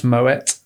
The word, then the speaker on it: Moët: I have also been reliably informed it's Moët. – Oh, really? – Apparently. – Oh, that's my mistake. Another mispronunciation from Greg.